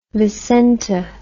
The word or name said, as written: Vicente